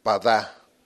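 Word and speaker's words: Padá (פדה): Y la otra es Padá, P-A-D-A-H al final, Padá.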